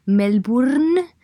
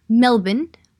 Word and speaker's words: Melbourne: De hecho, mm, en Melbourne, Melbourne, para los de Melbourne, que si alguna vez vais a Melbourne, no digáis Melbourne, porque la R aparece aparentemente no se pronuncia, así que tenéis que decir Melbourne, porque si no os miran como, pero ¿de qué piedra ha salido usted?